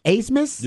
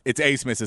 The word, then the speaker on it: Abmas: It is spelled Abmas, but it's pronounced Asemus. – Oh, there you go. I appreciate the correction. – Because I think he's German, if I'm not mistaken. I think there's some German talk. I heard I heard Jeff Howe talking about it. – I know that when I went back and I've watched game film of his and I've done and looked into it, it's Asemus is how it's pronounced. So it's spelled Abmas. It is pronounced as Asemus, though.